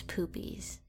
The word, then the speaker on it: Poopies: where Poopsie got it right and Johnny Knoxville still hit him in the nuts. – You're not even saying his name right. It's Poopies.